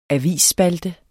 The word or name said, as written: avisspalte